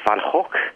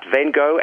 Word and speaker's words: Van Gogh: If you want to be good Dutch pronunciation, I think it is Van Gogh. – Yeah, that's true. – But Van Gogh and Van Gogh are also absolutely fine. – Anglicised version. – Anglicised versions, yeah.